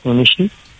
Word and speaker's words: Mounichy: M-O-U-N-I-C-H-Y, Monichi, A-R-S-E-N-E.